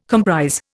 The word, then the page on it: comprise: Indian